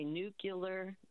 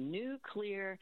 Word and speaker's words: nuclear: And then the third one that people mispronounce all the time is when they say nuclear, not correct. So if you, if it's cloudy today, and maybe, but tomorrow it's going to be a new clear day. Just say nuclear, and you will pronounce nuclear correctly.